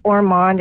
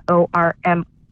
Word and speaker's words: Ormond: And it's Ormond, it's Ormond, not Ormand, which you'll see it's spelled that way.